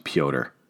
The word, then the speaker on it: Petr: P-E-T-R is how you spell his first fucking name. And it's pronounced Piotr.